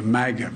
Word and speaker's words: MAGA: The mega Republicans, the MAGA Republicans.